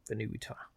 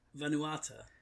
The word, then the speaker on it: Vanuatu: I know that he's God of a Venutan Village. So mm-hmm. It's not that. – Vanuatu. – Yeah, that one. – Yeah, I can't pronounce it. – Vanuatu. – Vanuatu, I think.